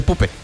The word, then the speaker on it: Puppe: Esa sí se pronuncia así, mire. Ahí sí no hay ninguna variación, se dice Puppe.